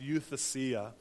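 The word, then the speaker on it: huiothesia: In the Greek, the original word that Paul uses for adoption is a word that's pronounced euthesia.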